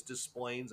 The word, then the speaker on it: Des Plaines: Um, and I'm saying that right for those of you who are not Illinoisans. It's it is Des Plaines. That's how you say it.